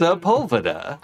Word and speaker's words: Sepulveda: Sepulveda.